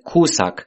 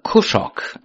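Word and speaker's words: Kușak: Also kennen ihn viele Genre-Liebhaber auch in der Aussprache Kuschak, mit K am Anfang und Ende geschrieben. Über seinen ethnischen Hintergrund konnte ich nichts herausfinden. In seiner Heimatstadt sind etwa 20% der Einwohner ungarischer Abstammung. Nach den Regeln der ungarischen Sprache müsste man den Namen Kuschok aussprechen.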